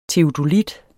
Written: teodolit

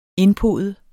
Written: Indpodet

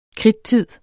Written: kridttid

